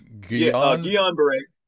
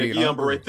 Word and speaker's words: Guillain-Barré: Guillain-Barré. Yeah, Guillain-Barré. Think of like a fancy cologne. That was the only way I could remember it myself. I was like, Guillain-Barré. – It's G-U-I-L-L-I-A-N, right? – Yeah. – B-A-R-R-E.